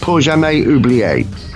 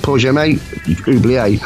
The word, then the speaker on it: Pour Jamais Oublier: I think the track is pronounced Pour Jamais Oublier. Like Pour Jamais Oublier, which I think means for never forgetting, or we never forget, or something like that, right? – Okay.